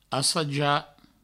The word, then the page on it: assajar: assaja